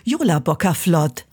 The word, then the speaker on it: Jólabókaflóð: Wie spricht man das aus? – Hallo, also das ist tatsächlich eins der einfachsten Worte, die es gibt. Jólabókaflóð. Also man spricht es tatsächlich fast, wie man es schreibt und das ist ein Wunder im Isländischen und Deutschen.